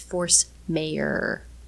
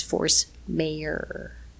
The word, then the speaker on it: Force Majeure: I'm not sure that was necessary, but anyway, so now we know going forward that it's one person's opinion is that it's force mayor. – Okay. I suppose that could be the Danish or whatever other language sure. he speaks pronunciation.